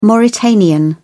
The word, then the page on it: mauritanian: British English